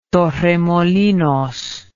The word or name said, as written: torremolinos